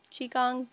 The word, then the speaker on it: Qigong: Qigong, there you go.